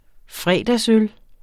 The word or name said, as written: fredagsøl